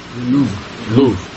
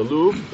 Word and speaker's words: Louvre: how do you say it, Louvre? The place where they have all the art? – Louvre. – Yeah, the Louvre.